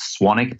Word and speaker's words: Swanwick: My last name is actually pronounced Swanwick, but you spell it Swanwick. So it's S-W-A-N-W-I-C-K, Swanwick Sleep.